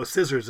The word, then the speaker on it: scissors: And scissors also got as you well know, scissors isn't spelled like it's, sounds either. It's got the C chucked in there too to make it like the Latin as well.